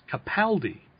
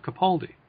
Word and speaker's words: Capaldi: One fun fact, I found out that his name is actually pronounced Capaldi instead of Capaldi.